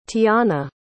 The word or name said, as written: teanna